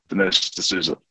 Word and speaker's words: Dinesh D'Souza: Dinesh D'Souza.